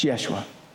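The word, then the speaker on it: Yeshua (ישוע): And what's wild about the Jesus-Joshua connection is that Jesus' name in Hebrew is Yeshua. It's Yeshua. Jesus is the Greek pronunciation, it comes from the Greek pronunciation of Yeshua. Do you know what Joshua's name is in Hebrew? Yeshua. Joshua is the English pronunciation of Yeshua.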